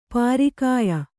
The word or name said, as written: ಪರಕೀಯೆ